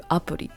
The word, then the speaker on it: アプリ: In Japanese, however, they shorten the word application to apuri. They take the letters A, P, P, and L, I of application, and they use those letters to make their own pronunciation. You can see how apuri sounds a lot different from apps.